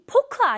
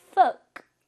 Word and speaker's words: folk: folk, 발음이 포크 아니에요? folk, folk입니다.